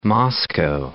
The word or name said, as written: Moscow